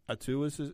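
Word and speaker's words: Omalu: And again, probably about 5 or 6 years before Dr. Attu, I think. Attu is how his name's pronounced.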